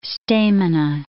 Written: stamina

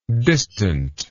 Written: distantness

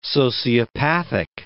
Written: sociopathic

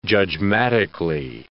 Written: judgmatically

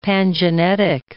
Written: pangenetic

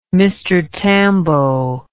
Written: Mr Tambo

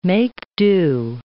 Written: make-do

